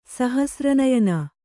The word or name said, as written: ಸಹಸ್ರನಯನ